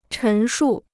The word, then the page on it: 陈述: chén shù